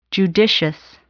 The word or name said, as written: judicious